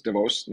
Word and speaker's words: Davos: Of course, this time I'm calling in from Davos, or Davos, as I've been reminded, the real pronunciation is Davos, not Davos.